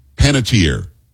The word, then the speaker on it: Panettiere: I usually say Hayden Panettiere. – Hayden, what did I say? Peyton Hayden. Hayden Pantiere. – I said Panettiere, yeah. – Panettiere. It's an Italian name. It'd be Panettiere.